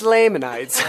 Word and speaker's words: Lamanites: And you know, I will say my one claim to fame with the Book of Mormon show is that they were pronouncing Lamanites, Lamanites. And I was like, excuse me. But it's pronounced Lamanites. And they fixed that.